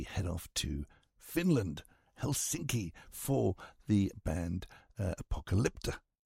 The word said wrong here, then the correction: Apocalyptica: Hang on, get it right. Apocalyptica.